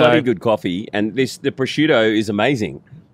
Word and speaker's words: prosciutto: Prosciutto.